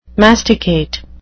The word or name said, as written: masticate